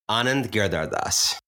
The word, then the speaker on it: Anand Giridharadas: Well, how would you pronounce the name? Go ahead. – Anand Giridharadas. – Giridharadas. All right. I'm getting closer.